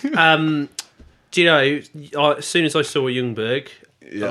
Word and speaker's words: Ljungberg: Because the J you don't pronounce. – Yeah, I know, but we've got a choice here. Like, why don't we just not pronounce the L and call him Ljungberg? – We can, but it's not his name.